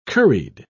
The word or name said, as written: curried